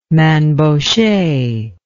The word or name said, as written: Mainbocher